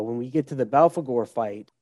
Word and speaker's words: Belphegor: Belphegor, yeah.